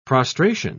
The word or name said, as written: prostration